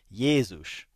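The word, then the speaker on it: Jézus: But I mean, if you look at other languages, you know, I lived in Hungary for 10 years, and over there, Jesus is pronounced Jezus, right? So it's a ye, it's just like Yeshua.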